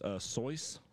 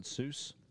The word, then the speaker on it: Seuss: You know, I heard it's actually pronounced Soyce, not Seuss.